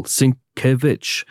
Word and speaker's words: Sienkiewicz: And the pronunciation of his name is helpfully his Twitter handle, Sinkevich.